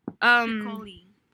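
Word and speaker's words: Piccioli: Now we're on 89, and then we're on Pier Paolo Piccololi, Piccololi, and Florence Q. and Valentino. Piccolo? – Piccoli.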